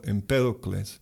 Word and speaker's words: Empédocles: Eso viene del filósofo Empédocles. Así se pronuncia, ¿verdad? En español, Empédocles.